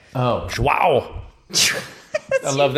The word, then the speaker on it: João: It's Joe with a Whoa. – Nope. – Joao. Joao. All right.